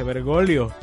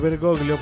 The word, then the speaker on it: Bergoglio: Pero bueno, eh, a ver, es que el apellido es italiano y, en italiano se pronuncia Bergoglio.